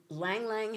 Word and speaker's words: Ylang Ylang: Y-L-A-N-G. Y-L-A-N-G. – Y-L-A-N-G.